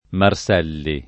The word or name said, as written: Marselli